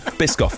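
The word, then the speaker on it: Biscoff: Rhymes with whisk off. – Well, no, okay. Bisque off then, yeah.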